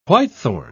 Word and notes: whitethorn: British English

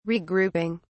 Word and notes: regrouping: British English

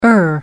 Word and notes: er: American English

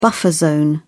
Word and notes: buffer zone: British English

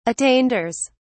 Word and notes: attainders: British English

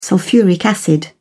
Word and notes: sulphuric acid: British English

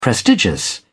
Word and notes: prestigious: British English

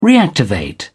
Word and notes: reactivate: British English

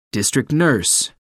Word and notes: district nurse: American English

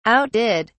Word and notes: outdid: British English

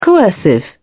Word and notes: coercive: British English

